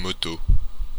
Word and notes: moto: [yn mo.to]

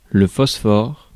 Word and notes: phosphore: /fɔs.fɔʁ/

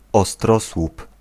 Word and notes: pyramide: France; [pi.ʁa.mid]; /pyʁamid/

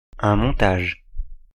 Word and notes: assembly: American English; IPA : [ə.ˈsɛm.bli]

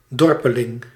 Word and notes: rustic: /ˈrʌstɪk/

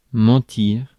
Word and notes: bull: /bʊl/

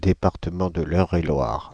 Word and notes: Eure-et-Loir: Paris; [œ.r‿e.lwaʁ]

